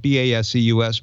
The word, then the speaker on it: Baseus: Uh, the brand is spelled B A S E U S, pronounced Baseus.